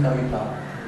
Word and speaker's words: Savita: but that you will learn, if you are right, when the Roman script is there, it is pronounced Hiranya and Savita. Then that Savita is ta, and it's not ta.